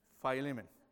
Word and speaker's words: Philemon: Today, we're going to be starting a new sermon series on the book on Philemon. I have to practice a lot to say his name, Philemon. And actually, in Spanish, it's Filemon. It's easier.